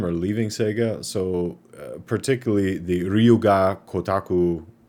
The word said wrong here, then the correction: Gotoku: or excuse me, Gotoku. Man, these names. Ryu ga Gotoku.